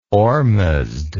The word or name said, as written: ohrmazd